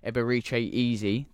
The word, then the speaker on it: Eberechi Eze: Let's talk a little bit about Eberechi Eze. Did I say that right? – Yeah, well done.